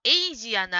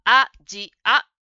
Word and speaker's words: アジア: それから答えの文のアジアならというところですが、ジムさんはエイジアならと英語のように発音していました。ここはアジアと 3 つの言葉をしっかり言いましょう。